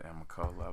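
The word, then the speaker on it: Amicalola: Most people pronounce it Amicola.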